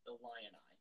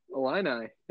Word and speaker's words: Illini: You guys are the first people that I have ever heard them say Illini. – Illini, yeah.